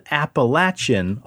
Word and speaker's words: Appalachian: Appalachian, Appalachian? – It's Appalachian. – We should call Rob real quick on the phone. – Apparently, if you live around that area, there is a saying called... If you pronounce it Appalachian, I'll throw an Appalachia. – Mm. – So I don't think it's Appalachian.